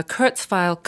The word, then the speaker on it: Kurzweil: Courtesy of Walton's Music, we have a Kurzweil Cup-2 upright digital piano.